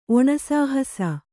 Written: ಒಣಸಾಹಸ

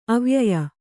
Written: ಅವ್ಯಯೀ

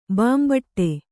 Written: ಬೆಂಬೆಟ್ಟ

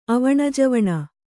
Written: ಅವಣಜವಣ

